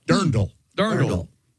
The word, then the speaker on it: dirndl: That does the dress is called a dirndl. I must have pronounced it wrong. I apologize. Dirndl is what that dress is called.